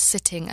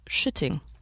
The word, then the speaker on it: sitting: not pronounce sitting as shitting.